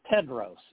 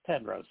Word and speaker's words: Tedros: well, it's Tedros. It's Tedros.